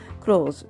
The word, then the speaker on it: clothes: これ clothes は服ですけれども、一応ね、 a lot of つくわけなので、複数形になって es がつきますが、発音は clothes という感じになりますね。